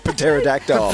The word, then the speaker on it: Pterodactyl: T, not wrong. What, P, where? – Pterodactyl. – Pterodactyl, isn't it? – Fair enough.